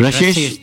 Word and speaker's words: Rashesh: But before that, we have Mr. Dalal, Rashesh, is that how you pronounce it, sir? – That is correct. – Rashesh Dalal.